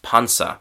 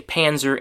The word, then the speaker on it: Panzer: The German word, German pronunciation, sorry, is Panzer. And so, in English, we just, as I said, we say Panzer,